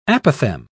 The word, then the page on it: apothem: British English